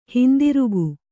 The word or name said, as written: ಹಿಂತಿರುಗು